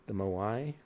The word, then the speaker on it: moai: Did a lot of sleds to move the Moai. That's spelled M-O-A-I.